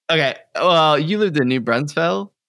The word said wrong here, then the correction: New Braunfels: You said it wrong. It's New Braunfels.